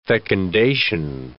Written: fecundation

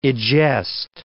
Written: egest

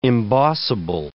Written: embossable

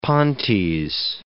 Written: pontes

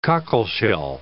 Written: cockleshell

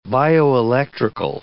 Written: bioelectrical

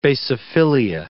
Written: basophilia

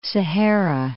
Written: Sahara

